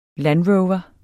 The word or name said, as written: landrover